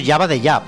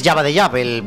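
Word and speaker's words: Jabba the Hutt: Espera un momento, has dicho Jabba the Jab. – Sí. – Pues es que lo has pronunciado un poco raro. Es Jabba the Jab,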